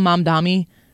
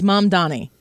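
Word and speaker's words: Mamdani: They call him Mom Dami a lot. They can't get his name right. – It's like Mom, your mother, whose name is Donnie. It's Mom Donnie.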